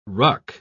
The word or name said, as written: ruck